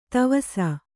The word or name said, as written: ತವಸ